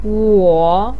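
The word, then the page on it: wo: tone 3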